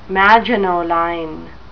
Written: Maginot Line

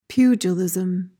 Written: pugilism

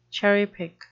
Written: cherry-pick

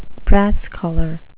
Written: brass-collar

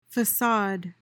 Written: facade

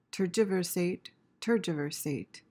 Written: tergiversate

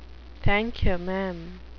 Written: thank-you-maam

